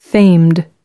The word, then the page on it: famed: American English